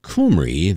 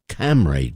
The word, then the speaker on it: Cymru: Yes, Kumri, the Sinfonia Kumri, even though it's spelled C-Y-M-R-U, is pronounced Kumri or Camry, depending on what region in Wales you are from. Yes, there are different accents even in a country the size of Wales.